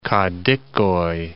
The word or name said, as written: Kadikoy